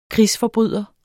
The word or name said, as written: krigsforbryder